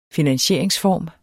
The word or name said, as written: finansieringsform